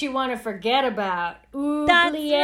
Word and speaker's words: oubliette: I don't know if I'm pronouncing that correctly. – Oubliette. – The L was in the wrong spot for me. It's oubliette. You're correct.